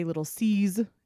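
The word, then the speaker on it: Lil' Cease: And I say Lil C's, it's spelled Lil Cease, but it's short for Little Caesar.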